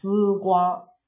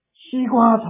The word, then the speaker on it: 丝瓜: 卷舌音啊，我们没有这两种发音方式的啊，一二三四啊，我们一定是一二三四，没有这个音嘛哈啊，丝瓜就是我们就会读成西瓜，对了啊。丝瓜炒牛肉，我们一定变成西瓜炒牛肉了哈。